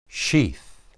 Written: shith

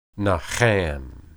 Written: naham

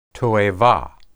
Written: toebah

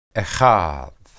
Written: echad

